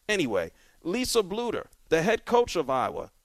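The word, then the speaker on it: Bluder: Shep, is it Bluter or Blutter? How do you say this lady's name? – Oh man, I'm pretty sure it's Blutter.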